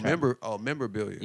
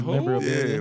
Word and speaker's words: memorabilia: You said Abelia. – Yeah, Abelia. I thought you was about to memorabilia. – Yeah, like who was Abelia? – Memorabilia. I get it.